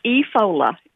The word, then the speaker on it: Efola: Well, my middle name is Efola, E-F-O-L-A.